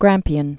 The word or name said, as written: Grampian Mountains